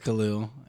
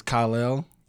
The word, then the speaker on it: Khalil: So everybody says Khalil, and I'm fine with that. But technically, it's Khalil.